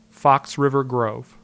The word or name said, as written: Fox River Grove